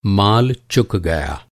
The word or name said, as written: चुकना